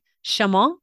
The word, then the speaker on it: chemin: French is more difficult for me because like Chemin, what, the N, you don't get to pronounce the N.